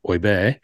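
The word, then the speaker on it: Åberg: Now, I'm going to obviously put it down to me sending Gabriel Hertzstedt's correct pronunciation of how to pronounce Oiberg.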